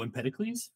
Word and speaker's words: Empedocles: Oh, Empedocles? – Yep.